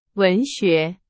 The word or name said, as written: 文学